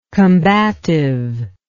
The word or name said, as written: combativity